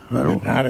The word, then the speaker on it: Bernhardt: Tienes que pronunciar francesa. – Bernard, entonces Bernard, ¿no?